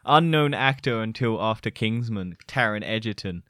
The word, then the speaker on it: Taron Egerton: It's kind of a similar story with like Taron Egerton. Taron Egerton, yeah.